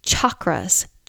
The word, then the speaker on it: chakra: It does not have a soft sh sound at the beginning. I'm not even going to say it the way I hear it often, but it's a hard ch, ch sound, like chocolate. Chakras, chocolate.